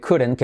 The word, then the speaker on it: couldn't: De como resuave, couldn't, que lo curen, que está malo. Couldn't make it. Incluso la T de couldn't no se pronuncia, ¿vale?